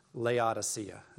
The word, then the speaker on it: Laodicea: Laodicea is how you pronounce it.